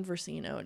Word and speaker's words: Versino: My name is Lauren Versino. It is spelled exactly how you think it sounds.